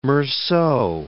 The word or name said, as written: Meursault